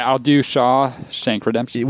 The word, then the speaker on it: Shawshank: It's one word. Shawshank.